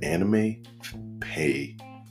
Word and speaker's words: AnimePahe: There's this website called Anime Pay, it's spelled AnimePahe.